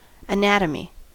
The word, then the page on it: anatomy: American English